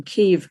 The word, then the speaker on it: Kyiv: And his parents, when things got rather tough in Kyiv, as, and I'm using the Ukrainian pronunciation there, during the Second World War, they upped and walked to Naples, and this took them a few years.